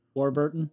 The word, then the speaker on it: Warburton: How do you pronounce his name? – Warburton. – Warburton, thank you.